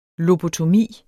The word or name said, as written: lobotomi